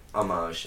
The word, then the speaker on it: homage: Homage. Am I saying that right? – Homage. – Homage. – Something like that, yeah. – Excuse me. I see it was a French word. – Yeah. – Like blancmange.